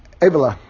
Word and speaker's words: Avila: I think it's actually Avila, because I didn't get the accent on my screen here, but I think when it's accented, it's on the first syllable.